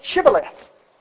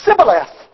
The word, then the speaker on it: Shibboleth: An Ephraimite could not pronounce the word that began with S-H, Shibboleth. They would leave out the H and they would say Sibboleth because they had trouble for some reason pronouncing that word.